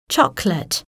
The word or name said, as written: chocolate